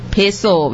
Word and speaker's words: peso: Peso, which is rhymes with queso.